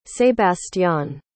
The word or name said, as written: sebastián